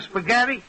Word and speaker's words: spaghetti: An H in a spaghetti? – Sure, only the H is silent.